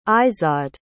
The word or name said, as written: izod